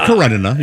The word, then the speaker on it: Karenina: It's Karenina.